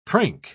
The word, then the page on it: prink: British English